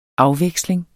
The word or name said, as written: afveksling